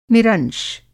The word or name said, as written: निरंश